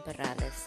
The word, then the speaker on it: Perales: And this is your host, Kim Perales. If you cannot roll your R's, it's okay. Just call me KP.